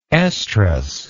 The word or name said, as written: oestrous